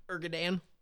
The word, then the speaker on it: Erdogan: Erdogan? – That's a correct pronunciation.